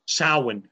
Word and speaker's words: Samhain: Celebrations like Halloween, Samhain, Samhain, as it's pronounced, Christmas, Midsummer, where do these come from?